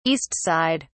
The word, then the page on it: east side: British English